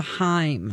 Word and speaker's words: Haim: I actually was saying Haim, and I was corrected, I think, to Haim.